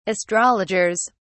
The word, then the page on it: astrologers: British English